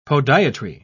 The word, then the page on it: podiatry: British English